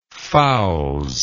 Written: fouls

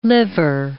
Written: liver

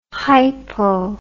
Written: hipo

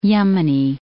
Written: Yemeni

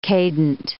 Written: cadent

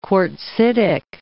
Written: quartzitic

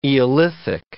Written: Eolithic

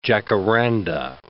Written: jacaranda

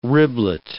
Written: riblet

